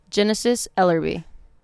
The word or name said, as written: Jhenesis Ellerbe